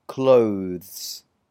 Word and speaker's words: clothes: It's really quick the way it goes from the th to the s sound. So you have to be quite fast with this, clothes. So practice going th to s, th, th, clothes. Difficult to pronounce. Clothes. Okay, number two. G-O-V-E-R-N-M-E-N-T.